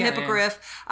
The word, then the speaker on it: hippogriff: hippogriff. No. Am I saying that right? – The hippogriff.